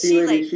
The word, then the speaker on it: chelating: I thought it was chelating, but it's chelating. I've been saying it wrong this whole time.